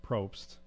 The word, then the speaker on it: Probst: As I see Jeff Probst, that's how his name's pronounced.